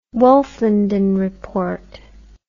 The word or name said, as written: wolfenden report